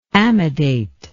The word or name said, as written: amidate